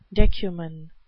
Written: decuman

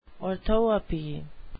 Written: orthoepy

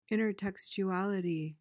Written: intertextuality

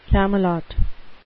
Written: camelot

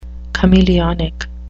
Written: chameleonic

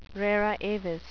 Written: rara avis

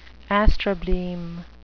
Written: astrobleme

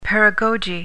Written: paragoge

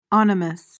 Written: onymous

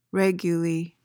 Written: raguly

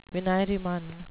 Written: venireman

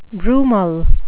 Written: brumal

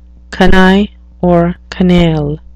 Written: canaille

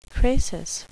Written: crasis

